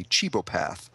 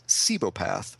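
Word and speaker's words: cibopath: He's Chew, C-H-U, and he chews things. And up until I looked this up today, I was pronouncing what he is as a chebopath. But when I looked it up today, I guess Wikipedia told me it's pronounced cebopath.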